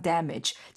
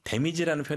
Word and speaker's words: damage: that could be causing some damage. 자, 데미지라고 하는 단어 damage가 나왔죠. – 네, 여기서 발음이 중요하죠. – 네. – 데미지라는 표현도 많이 쓰긴 하잖아요, 네. – 한국에서. 영어로는 damage.